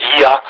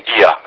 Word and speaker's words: Oia: Oia apare pe hartă, înțeleg că se pronunță Ia, cum pronunță ei aici și intern.